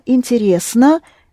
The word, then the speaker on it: интересно: Рес, stressed. Это интересно.